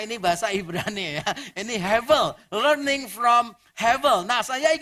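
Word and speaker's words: Hevel (הבל): Jangan emosi, ini bahasa Ibrani, tulisannya Hebel tapi bacanya bukan B tapi V. Jadi kalau dalam tulisan Ibrani, kalau ada huruf B, bet namanya, kalau di tengahnya tidak ada titik, dibacanya V. Jadi bacanya Hevel, Hevel.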